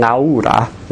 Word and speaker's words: Laura: So there's her name, Laura, or in Italian, Laura. They would pronounce all the syllables.